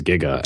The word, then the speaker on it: giga: There's an interesting Wikipedia entry I will add to our show notes as well, because it says the initial G of Giga can be pronounced a hard G like giggle, or a soft G as in giant.